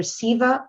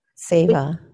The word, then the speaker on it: Seva: It's either Siva or Seva. Seva. seva. – Yeah, keep doing Seva, which is selfless service.